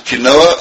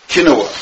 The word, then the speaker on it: quinoa: There are many people, and this shayla has been brought to me a number of times, who eat something called quinoa. Other people pronounce it as quinoa.